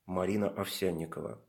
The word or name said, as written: Marina Ovsyannikova